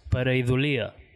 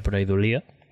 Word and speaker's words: Pareidolia: En castellà és pareidòlia, crec que... Però ho vam demanar a un erudit lingüista amic nostre que mos va dir que ho havíem de pronunciar pareidòlia, ¿vale?